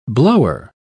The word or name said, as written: blower